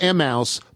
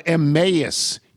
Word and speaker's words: Emmaus: It's not Emmaus, but Emmaus, E-M-A-Y-U-S, Emmaus.